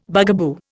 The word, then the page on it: bugaboo: Indian